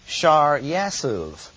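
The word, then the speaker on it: Shear-jashub: The Hebrew simply says, your son, Shar Yasuv. And there's no way you can get Shar Yasuv out of this. So we'll go ahead and mispronounce his name by anglicizing it and call it where? Shir Jashub, I guess.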